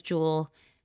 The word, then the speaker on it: Jewel: It rhymes with Shmuel. Yes, that's right. Her name is Jewel.